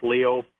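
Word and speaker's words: Lyot: It's spelled Liat. It's the French pronunciation, it's Leo.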